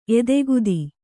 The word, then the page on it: ಎದೆಗತ್ತಿ: kannada